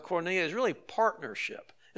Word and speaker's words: koinonia: The word here in Greek is a word you've heard before, the word koinonia. If you're taking notes and don't know how to spell it, it's K-O-I-N-O-N-I-A, koinonia.